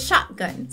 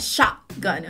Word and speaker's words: shotgun: The farmer was running towards me with a shotgun. Está vendo até que eu pronunciei, né? Shotgun. Eu não fiz esse som do T. Shotgun. Eu falei já interrompendo o T, ficando shotgun.